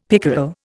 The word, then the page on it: pickerel: Indian